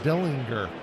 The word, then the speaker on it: Dillinger: And actually, for all you gangster fans out there, it's actually pronounced Dillinger.